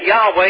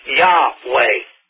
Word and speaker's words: Yahweh: Yahweh is the correct way to pronounce his name. That the Y-A-H in his name sounds like I just said it, Yah, and that the Wah in his name sounds like way, Yahweh.